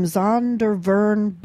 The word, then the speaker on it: Zondervan: It's the new international version from Zondervan Bibles. Did I pronounce that right? – Yes.